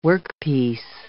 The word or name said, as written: workpiece